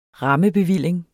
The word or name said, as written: rammebevilling